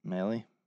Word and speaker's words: Maile: I don't know how to pronounce M-A-I-L-E. – Male. – Is that male? Is it Maley? – Maley.